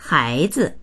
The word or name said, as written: hai2zi